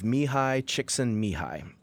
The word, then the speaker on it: Mihaly Csikszentmihalyi: And it was developed by, and I've worked on the pronunciation of this, a gentleman by the name of Mihaly Csikszentmihalyi.